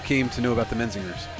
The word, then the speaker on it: Menzingers: I just, from what other people say, is Menzingers.